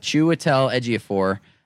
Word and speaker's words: Chiwetel Ejiofor: And also, I think another actor that I have immense respect for, and I'm very glad that you gave me the pronunciation on this one, Dom, because I'm not perfect at this one, but Chiwetel Ejiofor.